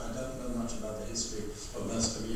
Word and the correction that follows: Merstham: even though Merstham, I must remember that, because every time I say it wrong, Tony looks at me as a teacher, a school teacher, it's a little ruler out and he threatens me. No, it's Merstham, and